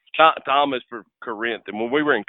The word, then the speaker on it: Corinth: but he would always correct me. Corinth. It's Corinth, man. Say it right.